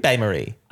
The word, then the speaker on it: bain-marie: Bain-Marie. – I don't think you need to hit the N. – Oh, okay. It's a silent N.